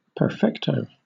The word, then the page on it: perfecto: British English